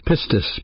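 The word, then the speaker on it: pistis (πίστις): But faith, the word faith, is a Greek word pronounced pistis, P-I-S-T-I-S, pistis.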